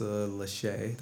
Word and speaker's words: Lachey: but they've had a couple and I think Vanessa Lachey, that's how you say her name,